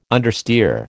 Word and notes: understeer: American English

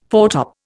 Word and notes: foretop: Indian

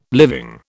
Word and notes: living: British English

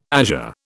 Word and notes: azure: British English

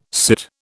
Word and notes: sit: British English

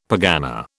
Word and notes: pergana: British English

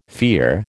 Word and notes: fear: American English

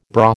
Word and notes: bra: American English

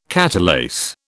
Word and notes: catalase: British English

